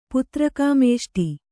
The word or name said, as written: ಪುತ್ರಕಾಮೇಷ್ಟಿ